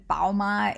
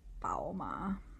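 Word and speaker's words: Bauma (宝马): And Bauma is Bauma. That's how you'd say it, I believe. Let me check my Google Translator. Bauma. And it just means BMW in Mandarin. Bauma. Yeah, that's how you say it.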